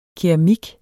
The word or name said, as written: keramik